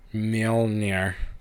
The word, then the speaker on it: Mjolnir: Mjolnir, I can't ever pronounce it correctly. – Mjolnir. Bless you.